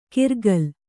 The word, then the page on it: ಕಿರ್ಗಲ್: kirgal